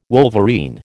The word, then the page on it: wolverine: American English